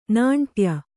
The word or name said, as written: ನಾಂಟ್ಯ